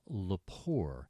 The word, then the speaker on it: Lepore: Yeah, The Prodigal Daughter, and once again, the writer, we always like to give proper attribution here, is Jill Lepore. Last name is spelled L-E-P-O-R-E,